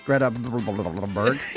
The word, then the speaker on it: Greta Thunberg: It was 16-year-old climate activist Greta Thunberg.